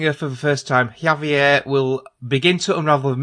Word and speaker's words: Javier: So the second character you play is, I don't know how you pronounce this, it's spelled J-A-V-I-E-R, is it Javier? – Javier.